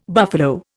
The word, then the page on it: buffalo: Indian